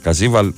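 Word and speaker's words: Καβίζελ: Ο Καζίβαλ προφέρεται. Πιστεύω το προφέρω καλά.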